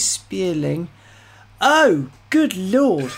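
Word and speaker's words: Spéirling: The car is called the McMurtry Spearling. Now, it's spelt M small c M U R T R Y. Yes, it's Irish. McMurtry Spearling is spelt S P E I R L I N G.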